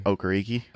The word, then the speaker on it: Okereke: Okereke. – Okay, Bobby Okereke.